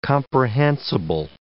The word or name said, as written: comprehensible